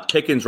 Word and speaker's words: Pickens: So and John and Dan, people forget that uh, Pickens rhymes with Higgins.